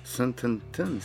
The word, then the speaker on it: Sententen: Sententen se imenuje. Sem prav izgovoril. Gre za slovensko besedo. – Čestitke, mislim, da si prvi, ki je prav izgovoril. – Prav, ne vem, v ljubljanski kotlini rečejo sententen. To sem slišal prvič. Tako da zdaj ne vem, kaj je res prav. Mi rečemo sententen.